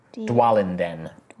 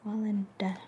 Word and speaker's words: Dwalinden: Dwalinden. – Could you spell that for me? – D-W-A-L. – A-L. – I-N. – I-N. – D-E-N. Dwalinden. – Dwalinden.